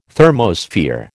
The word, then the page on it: thermosphere: American English